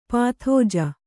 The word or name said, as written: ಪದಾಜಿ